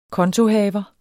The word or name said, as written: kontohaver